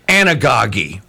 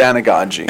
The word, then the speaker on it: anagogy: No, Mike, it's anagogy. You said it wrong. – Okay. – Just kidding. It's anagogy.